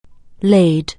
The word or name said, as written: lay